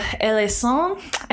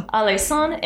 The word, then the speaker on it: Alençon: So Catherine heard that there was going to be a plot to overthrow her by the Duke of Alençon, I think. Oh, gosh. The French C with the curve under it, I think, is a soft C. Mm-hmm. Okay. So that's a hard one. – Alençon and Henry of Navarre.